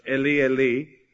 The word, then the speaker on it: Eli: It says, about the ninth hour, Jesus cried out with a loud voice, Eli, Eli, or actually it should be pronounced, Eli, Eli, lama sabachthani.